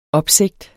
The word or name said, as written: opsigt